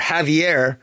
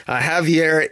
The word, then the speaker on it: Javier: So it might very well be that Xavier is in fact, or Javier, I always pronounce the J, I apologize to my Spanish listening friends. Javier